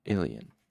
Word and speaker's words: Illien: That is I-L-L-I-E-N. Let me just see if that's in the pronunciation guide. Sorry, now I've discovered this pronunciation guide, I need to... Yeah, Illion. Okay, the next Illion.